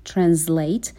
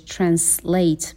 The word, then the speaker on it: translate: فعل translate هم میتونه صدای ز داشته باشه که بیشتر توی لهجه آمریکایی به این شکل تلفظ میشه translate، و هم میتونه صدای س داشته باشه که بیشتر توی لهجه انگلیسی به این شکل هستش translate به این صورت.